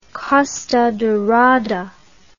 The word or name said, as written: costa dorada